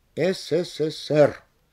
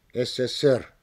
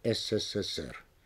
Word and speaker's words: СССР: Когда эти понятия даны в аббревиатурах, то есть в сокращениях, ударение должно быть сохранено на последнем слоге. СССР, КПСС. Кстати, нельзя говорить СССР вместо СССР. Если почему-либо трудно сказать СССР, можно сказать Союз СССР.